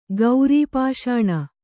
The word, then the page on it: ಗೌರೀಪಾಷಾಣ: gaurī pāṣāṇa